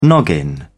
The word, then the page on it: noggin: British English